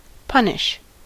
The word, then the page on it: punish: [ˈpʌ.nɪʃ]; American English